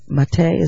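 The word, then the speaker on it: Maté: In your, in the foreword, you refer to Dr. Mate, is that how he pronounces it? – Yeah.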